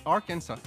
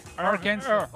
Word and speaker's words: Arkansas: Non, on dit Kansas, mais Arkansas. – Arkansas.